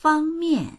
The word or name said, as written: fang1mian4